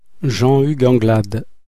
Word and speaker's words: Jean-Hugues Anglade: Jean-Hugues Anglade.